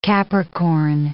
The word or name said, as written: Capricorn